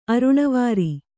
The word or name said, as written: ಅರುಣವಾರಿ